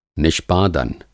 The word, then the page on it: निष्पादन: Hindi